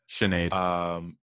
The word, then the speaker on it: Sinead: And a song called Settle with Sinead Harnett. – Sinead. – Is that how you say it? – Uh-huh. – Sinead. – It's a very Irish name, I think. – The H is not there at all.